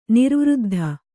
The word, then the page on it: ನಿರ್ವೃದ್ಧ: kannada